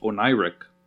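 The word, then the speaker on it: oneiric: Apparently, it's pronounced Oniric, and it has to do with dreams and dreaming.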